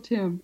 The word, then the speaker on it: Tymn: They're older books, but I recommend anything that Michael Timm, and his name is spelled T-Y-M-N, there's an N. It's like him. It rhymes with him, Michael Timm.